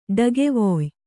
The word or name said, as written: ಡಕ್ಕೆವಾಯ್